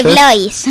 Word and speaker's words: DeBlois: DeBlois. – Si lo pronunciamos en francés, será DeBlois.